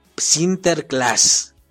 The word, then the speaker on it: Sinterklaas: Se pronunciaba así, Sinterklaas.